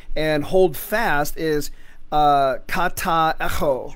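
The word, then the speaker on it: echo (ἔχω): Hold firm, which is help me with my pronunciation now. Echo. E-C-H-O, echo. Is that how you say it? – Yeah, so epsilon key omega.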